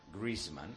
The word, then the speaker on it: Griezmann: A ver, para Antonio Ruiz y para todos los que queremos decir bien, Griezmann, ¿cómo se pronuncia Griezmann? ¿Lo tienes por ahí, Pedrito? – Sí, un momentito que le dé volumen a esto. Ahora. Si suena bien. – Griezmann. – Griezmann. No es Griezmann.